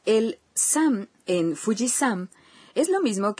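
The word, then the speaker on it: San (山): No, aunque se pronuncia igual. Montaña se dice... – Yama. – Pero se pronuncia... – San. – Cuando se coloca detrás del nombre de algunas montañas.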